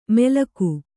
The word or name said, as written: ಮೆಲ್ಕು